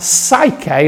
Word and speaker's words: psyche: And psyche or suke, I think suke is the correct pronunciation.